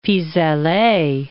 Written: pis aller

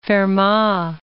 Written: Fermat

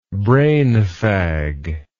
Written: brainfag

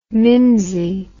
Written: mimsey

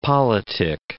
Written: politic

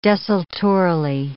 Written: desultorily

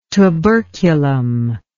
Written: tuberculum

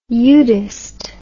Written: eudist

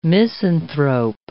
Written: misanthrope

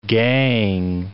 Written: gangue

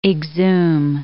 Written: exhume

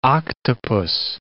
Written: octopus

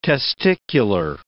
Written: testicular

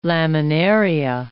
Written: laminaria

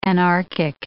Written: anarchic

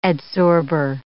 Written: adsorber

